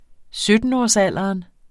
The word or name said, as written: syttenårsalderen